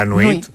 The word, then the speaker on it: nuit: Nuit. La nuit.